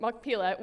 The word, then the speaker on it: Machpelah: that he may give me the cave of Machpelah, that's how you pronounce it, Machpelah, which he owns.